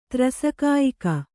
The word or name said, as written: ತ್ರಸಕಾಯಿಕ